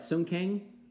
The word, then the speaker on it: Sung Kang: Sung Kang as Han? – Yeah.